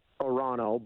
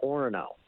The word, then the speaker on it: Orono: So it should be Arono, but we pronounce it Orono.